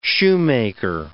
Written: shoemaker

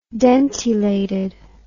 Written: dentilated

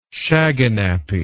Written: shaganappi

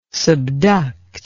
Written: subduct

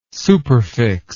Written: superfix

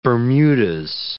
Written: Bermudas

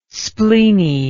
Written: spleeny

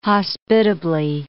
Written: hospitably

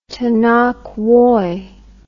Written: tena koe

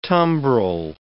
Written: tumbril

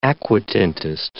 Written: aquatintist

